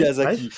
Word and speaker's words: Miyazaki: Miyazaki, j'arrive pas à le prononcer. Miyazaki. – Miyazaki, merci.